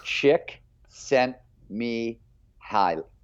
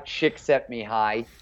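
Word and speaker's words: Csikszentmihalyi: Chick sent me hi. So it's Mihaly, Chick sent me hi with an E at the very end of it.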